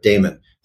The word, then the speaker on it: daemon: In the first book, there's this separating children, this machine to separate kids from their daemon, often pronounced demon, but daemon, the source of inspiration.